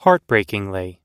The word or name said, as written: heartbreakingly